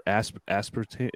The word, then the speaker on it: aspartame: Dude, there's nothing in it except for aspartame.